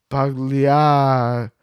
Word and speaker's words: Pagliacci: The G is silent. – Pagliacci.